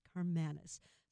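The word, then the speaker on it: Karmanos: You told us Carman, and then it rhymes with kiss, Carmanis.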